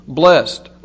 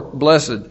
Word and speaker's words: blessed: You can pronounce it as one syllable, blessed or blessed, either one.